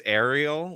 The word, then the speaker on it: Ariel: Apparently it's Ariel, like the Little Mermaid,